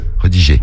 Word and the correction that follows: redirigé: Il oublie une syllabe. Redirigé. T'as oublié redigé. Non, c'est redirigé sur Snap.